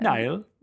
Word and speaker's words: Niall: Niall. Yeah. Okay. Niall. And they. So you have to say it like with an Irish accent. Niall.